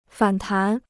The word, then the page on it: 反弹: fǎn tán